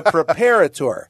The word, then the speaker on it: preparator: Thornton Triceratops Fossil Preparator. You pronounced that right. You helped me because on Channel 4 News, they got it wrong. The preparator.